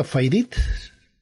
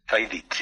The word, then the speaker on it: faidits: Pues entonces la pronunciación sería Faidits. – Faidits, sí.